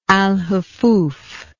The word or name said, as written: alhufuf